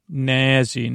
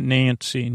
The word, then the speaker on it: Nazzy: Nazzy, not Nancy. Nazzy. Can't you say it like Nancy, but it's not Nancy, it's Nazzy.